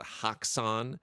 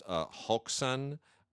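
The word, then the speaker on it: Häxan: People call it Haxan. It's actually probably pronounced Hoxan. Nonetheless, it is Haxan.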